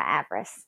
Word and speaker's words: Avarice: Well, my southern slang says avarice, but you're probably more correct on how it's actually supposed to be said. But yeah, I say Demora Avarice.